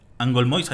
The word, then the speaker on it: Angolmois: y siete meses resucitará el gran rey de Algolmois. Al, Al Angolmois, así es, güey, la pronunciación.